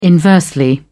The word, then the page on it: inversely: British English